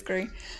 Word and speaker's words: Gris: Than, forgive my pretentious pronunciation, but than Gris. – Gris, excuse me. I think that is the correct way to say it, is Gris.